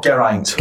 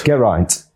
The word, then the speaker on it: Geraint: Oh, Geraint. – Geraint.